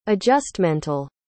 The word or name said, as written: adjustmental